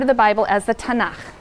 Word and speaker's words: Tanakh: Tanakh, which is really the letters T, N, and Ch. And they put little A's in there to make it easy to pronounce, because T is hard to pronounce.